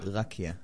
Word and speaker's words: Rakia: It should be more like, if it's an Israeli name, Rakia.